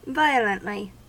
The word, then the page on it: violently: American English